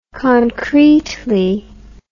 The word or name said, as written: concretely